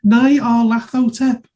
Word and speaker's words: Nyarlathotep: It says on the cover of second edition, it phonetically spells it out. Why do people keep on getting it wrong? Nyarlathotep. – There we go. Pronounced it properly for us.